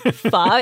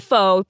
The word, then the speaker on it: pho: Well, especially ones that, you know, imply that pho rhymes with show. Or that it's yes. – pho. Yeah. – Oh, yeah. That is my biggest pet peeve. It's pho. It's not pho.